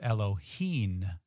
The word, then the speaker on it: Elohim: And it is not actually Elohim with an M sound at the end, but Elohim,